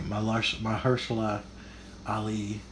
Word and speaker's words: Mahershala Ali: I really enjoyed what, and I'm going to butcher his name, Mahershala Ali.